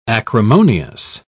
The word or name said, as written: acrimonious